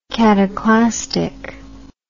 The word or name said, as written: cataclastic